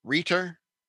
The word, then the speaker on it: Reiter: Markus Söder, the governor of Bavaria, along with Dieter Reeder, Dieter Reiter.